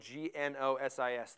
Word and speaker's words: gnosis: And does gnosis sound? It's spelled G N O S I S.